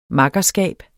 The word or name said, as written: MAKKERSKABS